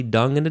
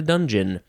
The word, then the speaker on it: dungeon: So, yeah, there's probably dung in a dungeon. Even though you don't pronounce it that way, it's not dungin. It's dungeon.